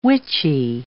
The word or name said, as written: witchy